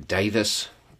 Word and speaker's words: Davies: It's happening in Davis County, Kentucky, which is, that's spelled Davies, if you're going to look it up.